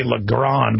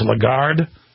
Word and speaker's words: Lagarde: I always say Lagrande, but it's Lagarde.